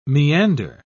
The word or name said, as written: meander